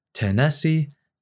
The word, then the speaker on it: Tennessee: To imagine the S4 sound, think of the word Tennessee, where the first syllable is the extra S4 sound, and the next two syllables correspond to S1 and S2. S4 is heard first because it occurs at the very end of diastole, immediately before the next heartbeat begins. It is pronounced this way, tenesi, tenesi. Notice that the extra sound is, again, low-pitched.